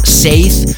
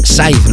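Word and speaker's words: Saiz: Siempre había pronunciado, creo, la versión sajona Seith y no es Scythe Luego vas a escuchar el saludo que nos ha grabado muy amablemente.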